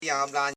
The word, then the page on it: jabla: ja.bla